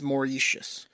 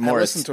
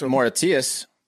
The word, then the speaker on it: Mauritius: Mauritius, I I name. Mauritius, Mauritius. I think it's M A U R I T I U S. I think it's Mauritius.